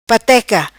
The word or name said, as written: Pateca